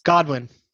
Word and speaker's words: Godwin: Godwin. – I think you said Godwin. – Godwin Evans. I might have said Goodwin. – Marquise Goodwin had that fantasy 5 games. – I might have mispronounced Chris Godwin.